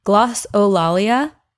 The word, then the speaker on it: glossolalia: And it was just part of, uh, I think it was called, it's called glossola, glossolia. Mm, yeah, I knew I wasn't going to be able to pronounce that. It's called glossolalia, which is speaking in tongues.